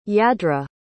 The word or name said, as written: yadra